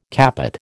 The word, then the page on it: capot: American English